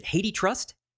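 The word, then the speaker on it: HathiTrust: Well, in a major ruling, a federal judge has tossed out an Authors Guild lawsuit against, how do you pronounce this? Is it Hathi Trust? – I think it's Hathi, but I don't know.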